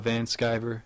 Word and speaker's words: Van Sciver: It's just Noah Van Sciver.